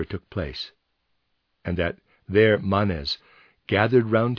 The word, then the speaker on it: Manes: Readers aside, I'm pronouncing that Manes. It's M-A-N-E-S. It might be Manes, but that just doesn't sound right to me.